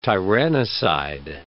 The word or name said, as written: tyrannicide